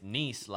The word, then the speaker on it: Nice: So the little town that you were raised in is called Nice. – Yeah. – And Nice. In English, if you read it, it's Nice, like, oh, nice. – Like, yeah. No, nice. Oh, like nice. N-I-C-E.